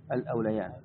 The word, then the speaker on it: الأوليان: يا أيها الذين آمنوا شهادة بينكم قد ذكرتها في كتاب صحيح مسلم، فإن عثر أي ظهر الأوليان هي تكتب بالياء لكن تنطق بالألف الأوليان في قراءة أوليان يعني فيها عدة قراءات.